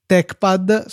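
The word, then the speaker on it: TeXpad: Mac, Mac Tech, scritto Mac Tex, è una distribuzione di LaTeX per Mac, va benissimo, e TechPad, scritto TechPad, anche questo, la X si legge sempre K quando si parla di LaTeX, ehm, è il nostro editor preferito in assoluto su tutte le piattaforme.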